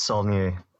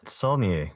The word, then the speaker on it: Saulnier: It's Saulnier. – Okay, Saulnier. You want to say it? Well, how do you say his name? – Saulnier. S-A-U-L. You know, N-I-E-R.